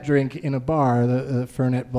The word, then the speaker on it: Fernet: And he goes, "As I sit there drinking my Fernie Blanca," and it's Fernet is how it's pronounced.